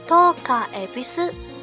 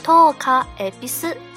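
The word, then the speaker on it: 十日恵比寿: 十日会比寿的日文发音呢，就是。托卡埃比斯，重复一遍，托卡埃比斯，就是拜惠比寿神的财神节了。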